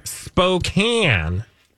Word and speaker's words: Spokane: Spokane. – Oh. – Even though it's spelled Spokane.